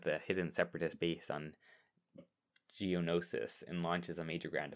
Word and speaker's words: Geonosis: G-E-O-N-O-S-I-S. Geonosis? Something like... – Geonosis. – Excellent, thank you.